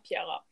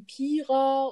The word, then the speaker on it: Piera: Und dadurch, dass die Truppe, in der wir damals unterwegs waren, beziehungsweise ja auf Tour waren, sage ich jetzt mal, den Namen Piera halt auch falsch ausgesprochen hat, wie Pira oder Piera, ja, habe ich einfach gesagt, nennt mich einfach Pi.